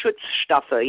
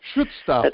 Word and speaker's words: Schutzstaffel: Schutzstaffel, is that correct in pronunciation? – Yeah, it's pretty good. Schutzstaffel, yes. – Schutzstaffel, that's right.